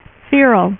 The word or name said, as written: feral